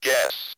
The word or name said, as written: guess